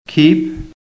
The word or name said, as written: KEEP